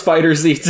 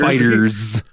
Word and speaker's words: FighterZ: Is it just Fighters? – It is just Fighters. – I always thought it was FighterZ too.